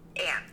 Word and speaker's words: Ann: So your name is it Anne or On? – Anne.